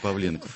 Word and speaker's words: Павленков: Солдатенков, Павленков или Павленков, не знаю, как правильно поставить ударение. – По-моему, все-таки Павленков. – Павленков, да, значит.